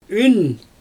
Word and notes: yn: Xyzyl

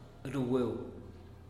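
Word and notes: rugueu: Teotitlán del Valle Zapotec

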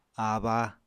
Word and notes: abba: Apatani

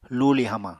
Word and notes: luuli hamañ: Apatani

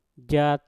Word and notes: jat: Mpur

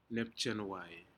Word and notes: nepjenwai: Aneityum

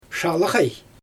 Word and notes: ʃalaɣaj: Xyzyl

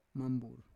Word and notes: mambur: Roon

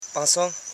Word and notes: panso: pan.so

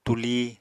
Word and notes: tulí: Apatani

